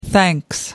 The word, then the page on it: thanks: American English